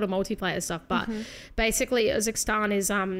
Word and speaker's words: Urzikstan: And the new big map, how do you pronounce it? – Urzikstan.